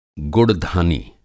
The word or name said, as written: गुड़धानी